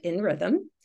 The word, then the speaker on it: nRhythm: An organizational design consultant at nRhythm, which I think I pronounced that right. – Yeah.